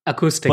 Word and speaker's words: Aqstic: I have no idea how to pronounce that. – Acoustic. But, acoustic.